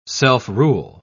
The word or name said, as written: self-rule